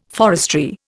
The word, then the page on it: forestry: Indian